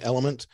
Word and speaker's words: LMNT: But if you look it up online, it's just the letters LMNT, but you pronounce it Element.